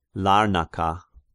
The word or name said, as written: Larnaka